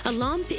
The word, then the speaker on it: Olympe: Olympe is spelled O-L-Y-M-P-E, but in French, it's pronounced Olympe.